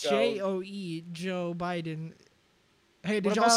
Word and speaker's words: Djo: Well, yeah, that's how you say it. DJO, Joe.